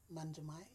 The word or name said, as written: Manjamai